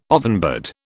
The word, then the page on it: ovenbird: British English